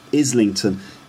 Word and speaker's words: Islington: Islington. Islington is an area in kind of northeast London, or North London, Islington. But he says Islington, or Islington, because he doesn't pronounce the T sound, just like Islington.